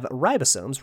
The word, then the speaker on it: ribosome: Doyle McPoyle, who is a Cowboys fan, says, mitochondria have ribosomes. Ribosome rhymes with dome.